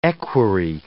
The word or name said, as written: equerry